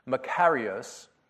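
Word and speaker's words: makarios (μακάριος): So there is this Greek word that appears in the New Testament that is pronounced makarios, and it's translated in our New Testament as blessed or blessed.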